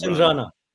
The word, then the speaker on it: Chandrana: Chandrana.